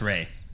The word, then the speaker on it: Ralph: It's spelled Ralph, but it's pronounced Ray.